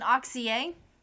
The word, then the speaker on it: Auxier: Right now I would have to say Jonathon Auxier. – Is that how you pronounce it? – Yes.